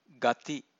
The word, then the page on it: gathi: Pali